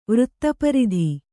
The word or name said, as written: ವೃತ್ತಪರಿಧಿ